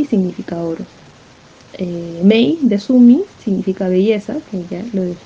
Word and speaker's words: Soomei: su nombre se escribe S-O-O-M-E-I. Mi mamá consideró que el nombre era muy complicado y, o sea, se pronunciaba Sumi, entonces ella decidió ponerme la pronunciación del nombre, Sumi.